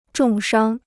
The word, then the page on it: 重伤: zhòng shāng